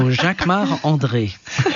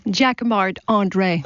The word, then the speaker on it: Jacquemart-André: You say it like André. – A Frenchman. Jacquemart-André. – Of course, because I'm French. So Jacquemart-André.